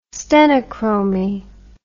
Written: stenochromy

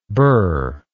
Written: bur

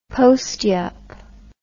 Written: postyup

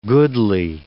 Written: goodly

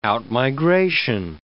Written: out-migration

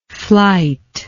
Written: flyte

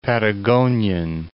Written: Patagonian

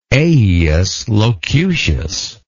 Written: aiuslocutius

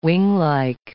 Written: winglike

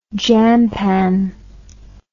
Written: jampan

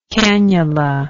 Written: canulation

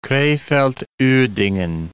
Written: Krefeld-Uerdingen

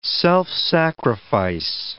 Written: self-sacrifice

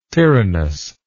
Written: tyrannous

